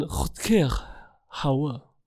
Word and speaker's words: Rutger Hauer: Aber es ist ein richtiger Holländer. Was? Deswegen spricht man eigentlich dann Rutger Hauer. So spricht man den aus.